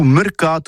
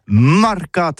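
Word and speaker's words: marcat: Eh bien, dis ça à tous, mais le mercat de l'Uni ou le mercat des rebelles, mais en gascogne toulousain, c'est prononcé sur les marcats, avec un A marque.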